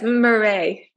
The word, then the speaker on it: Mare: Yes, Mare.